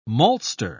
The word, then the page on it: maltster: British English